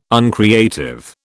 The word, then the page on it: uncreative: British English